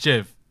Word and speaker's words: GIF: The whole thing about it being graphic and the G, that I don't know about, but I know it's pronounced GIF. The guy that invented it said that it was pronounced with a J, like GIF.